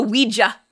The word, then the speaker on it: Ouija: compartments and things move and stuff like that and the first one we find is that the kid finds a Ouija board. – Yes, which they call a Ouija. – Yeah. Which is an acceptable pronunciation of the original word.